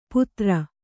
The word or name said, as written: ಪುತ್ರ